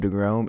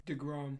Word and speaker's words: deGrom: DeGrom. – Is that how you say his name? – Yeah, it's DeGrom, not DeGrom. – DeGrom, that's what I said. – Yeah, it's DeGrom. – Okay, DeGrom. Jacob DeGrom.